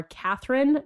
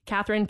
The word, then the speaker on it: Katherine: So my Twitter and Instagram handles are Katherine, not IRL. So Katherine, K-A-T-H-E-R-I-N-E, because there's so many ways to spell it.